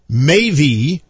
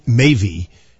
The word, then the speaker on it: Maebh: Well, Mavy, which is, it's spelled M-A-E-B-H, which is the Irish spelling of that word, Mavy, which means to cause to be joyous, I believe.